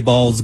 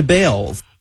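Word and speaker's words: Baals: Some people think the Harry Balls Government Center would make Fort Wayne the butt of jokes. After all, the family did change the pronunciation of their last name from Balls to Bales.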